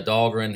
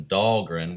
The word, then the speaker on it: Dahlgren: And I think the way I've been pronouncing Dahlgren, which is D-A-H-L-G-R-E-N, was being confused with dog run.